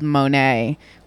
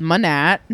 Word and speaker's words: Monat: So I mentioned this company called Monet, which we thought was called Monat. – Monat, yes. – Because it's spelled M-O-N-A-T, like the word would be spelled and said. – Right. – Monat. – But instead it's like the French painter.